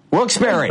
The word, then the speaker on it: Wilkes-Barre: Wilkes-Barre, Pennsylvania. Is it Bear Bar? How do you say that, Pennsylvania? It's Wilkes Bar, isn't it? – Wilkes-Barre. – Okay, thank you.